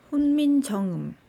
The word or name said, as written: hunminjeongeum